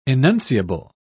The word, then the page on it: enunciable: British English